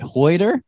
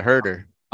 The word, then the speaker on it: Huerter: How do you say it? – Huerter. Kevin Huerter. Yeah, Huerter.